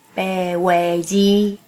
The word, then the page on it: 白話字: Pe̍h-ōe-jī; [pe˩ˀ o̯e̞˩ d͡ʑi˧]; Taiwanese Hokkien